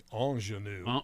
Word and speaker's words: ingenue: The definition, pronunciation is actually ingenue.